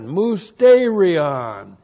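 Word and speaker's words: mustērion: M-U-S-T, long E-R-I-O-N, mousterion, church age truth that was not known about in Old Testament times.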